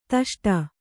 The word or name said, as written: ದುಷ್ಟು